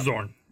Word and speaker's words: xorn: But is it Zorn? How do you say it in D&D? The X-O-R-N. – Yeah, it's Zorn. You got it.